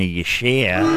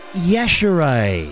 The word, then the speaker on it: Yashere: I've been calling her Gina Yashere. Wrong! Out of the pool! It's Gina Yashere.